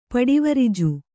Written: ಪಡಿವರಿಜು

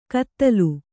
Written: ಕದಲು

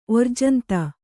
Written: ಓರ್ಜಂತ

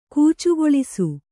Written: ಗಚ್ಚಗೊಳಿಸು

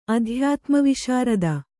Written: ಅಧ್ಯಾತ್ಮವಿಶಾರದ

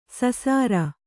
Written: ಸಸಾರ

